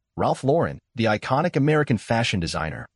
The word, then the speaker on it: Lauren: Is it Ralph Lauren? – Yep. – Yeah. – Then we get this email. It says, it's pronounced like the girl's name Lauren. It is not with an accent.